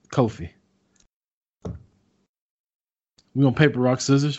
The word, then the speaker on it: Kofi: It's almost like coffee, but pronounced different. – We can go with Kofi. We can do Kofi.